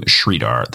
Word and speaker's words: Sridhar: My full name is Andrew Sridhar. That is S as in Sam, R-I, D as in David, H-A-R.